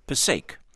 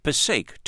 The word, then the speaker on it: Passaic: Or, as listener Bill Walker, who was born in the city of Paterson, New Jersey, corrects us, Passaic. He writes, Passaic, two syllables. The only people who use the three-syllable pronunciation are New York announcers who never bother to learn the correct way to say this word.